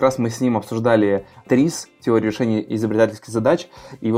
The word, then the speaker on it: ТРИЗ: Но э, под ТРИС педагогикой мы так и произносим. ТРИС, дефис педагогика.